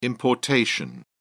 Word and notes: importation: British English

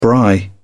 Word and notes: braai: British English

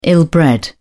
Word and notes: ill-bred: British English

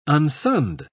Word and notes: unsunned: British English

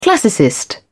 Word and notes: classicist: British English

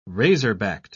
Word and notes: razor-backed: British English

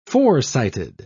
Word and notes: foresighted: British English

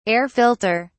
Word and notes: air filter: British English

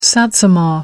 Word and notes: satsuma: American English

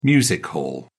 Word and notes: music hall: British English

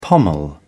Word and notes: pommel: British English

